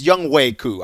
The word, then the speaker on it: Younghoe Koo: And by the way, it's Younghoe Koo. I want to make sure I pronounce his name right.